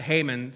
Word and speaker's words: Haman: Now, I'm saying Haman, but if you look at his name, it looks like He-Man, and I'm a child of the 80s. So just so you know, there is a He-Man in the Bible, okay? But I'm going to pronounce it Haman so we don't get him mixed up, okay?